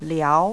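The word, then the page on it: liao: language Chinese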